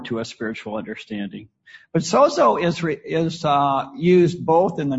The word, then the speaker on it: sozo: It's Strong's number 4982, and it's sozo. It's S-O-Z-O. That's how I pronounce it. Sozo, I think that's right.